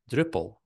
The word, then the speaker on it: Drupal: And I said, how would an English speaking person pronounce the Dutch words Drupal or drop? And like, oh, Drupal. And that's how I came up with the name.